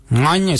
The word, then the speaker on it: Ngany: Ngany is spelled N-G-A-N-Y, Ngany.